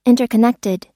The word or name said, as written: interconnected